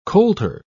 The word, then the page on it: coulter: British English